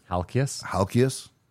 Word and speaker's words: Halkias: Stavros Haiokakis, 1909. – Say his name properly. – Haikias. – Keep going. – Halkias. I think that sounds about right.